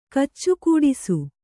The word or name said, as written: ಕಚ್ಚುಕೂಡಿಸು